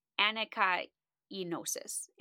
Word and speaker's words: anakainosis: The word in Greek used for renew in this verse is anakinosis. Anakinosis. Kind of said it right. A-N-A-K-A-I-N-O-S-I-S.